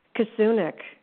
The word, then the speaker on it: Kasunic: Kasunic. Diane Kasunic.